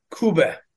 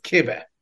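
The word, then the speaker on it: kibbeh: There's a food called kube, also sometimes pronounced kibe.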